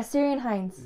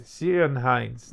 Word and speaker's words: Ciarán Hinds: Kieran Hines. – Oh, yeah, Ciarán Hinds. – Ciarán Hinds.